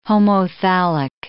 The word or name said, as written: homothallic